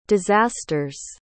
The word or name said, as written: disasters